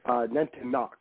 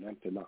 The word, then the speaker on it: Nantiinaq: Now, the local word for this Sasquatch type being is Nantiinaq. Am I pronouncing that right? – Nantiinaq, kind of like you knock on a door at the end. Nantiinaq. – Okay, Nantiinaq.